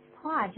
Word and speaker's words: Paul: Paul sends you his regards. Paul sends you his regards. How nice of him. How nice of him. – Paul, 别忘了用一个 L 结尾啊，所以变成 Paul。如果念 Paul 的话是 P-A-W。那 Paul 那是什么东西啊？ – Paul 就是爪子啊。 – 所以爪子。可是呢是 P-A-U-L, 这是个人的名字，有个 L, 怎么发音呢？ – Paul.